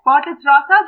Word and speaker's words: Bartlett's rata: so I might butcher this, but Bartlett's rata? – Not bad. Bartlett's rata, that's correct.